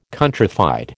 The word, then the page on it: countrified: American English